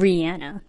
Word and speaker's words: Rihanna: Or Rihanna. That's how you properly say her name.